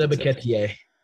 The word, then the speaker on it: Ebiketie: Ebi TK. I can't Arnold Ebiketie.